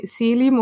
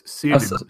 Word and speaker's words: Selim: Like Celim, of hoe, hoe spreek je het uit? Ik heb die, bijna niet gezien, hè? – Allee, Selim, je zegt het toch zo? Wie Selim? Mhm. Het is Selim. – Salim is ah, Selim.